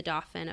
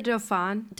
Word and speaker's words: Dauphin: But the French quickly propose their own marriage alliance, and Mary is betrothed to the Dauphin of France. – And isn't that the Dauphin? – Dauphin, yeah. Sorry, my pronunciation was wrong.